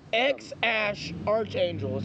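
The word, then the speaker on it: X Æ A-Xii: So it's X-Ash Archangel is how you say it.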